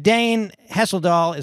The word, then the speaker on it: Dane Hesseldahl: And Dane Hesseldahl, is that the right pronunciation? – You got it.